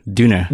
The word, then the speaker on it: Döner: like in what Berlin would be like Döner. Döner. Döner. – Yeah. With the, the umlaut.